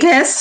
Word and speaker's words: casque: A casque. – How do you spell that? – C-A-S-Q-U-E.